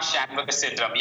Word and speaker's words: Shanmugasundaram: Yeah, Suddan Shanmugasundaram.